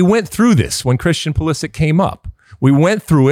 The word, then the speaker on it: Pulisic: Says it's pronounced Pulisic, C-H sound.